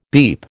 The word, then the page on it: beep: American English